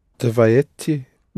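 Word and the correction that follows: Davajte (давайте): Davajte. A will be accented. Davajte. Pronounce it again, please. – Dvajeti. – Yes, davajte.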